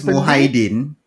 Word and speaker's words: Muhyiddin: And then for official documents, his actual name is Mahiaddin. So it's Muhyiddin. – Muhyiddin. – Yeah. From what we know, that's how you pronounce it, lah.